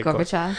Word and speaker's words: Gorbachev: Is it Chav? How do you say it? – Gorbachev. – Gorbachev? – Yeah.